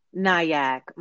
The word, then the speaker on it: Nyack: Is it Nyack? Is the N silent? – Nyack. – Nyack, okay.